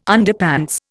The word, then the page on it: Underpants: Indian